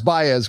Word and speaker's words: Baez: The young defender, it's pronounced Baez, correct? – Yes, Elias Baez, yes.